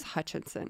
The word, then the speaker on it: Hutcherson: If you had asked me, thought it was I would have bet $10 million it was Hutcherson. – Yeah, did I say it wrong? I might have said it wrong. – No, I think you're right. I think it is Hutcherson.